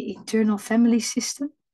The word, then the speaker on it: Internal Family System: Want we hadden het over dat Ah, wat was het, de Internal Family System?